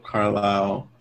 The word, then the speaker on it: Carlyle: Barnum persuades Philip Carlyle.